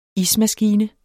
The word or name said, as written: ismaskine